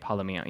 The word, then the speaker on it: Palomino: Did I say that right? Palomino. Yeah.